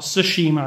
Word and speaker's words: Tsushima: It was when Tsushima Island, or Tsushima Island, if you say it correctly, was invaded by the Mongols, which, do you know when that was in time? – I don't.